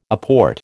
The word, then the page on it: apport: American English